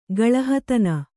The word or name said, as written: ಗಳಹತನ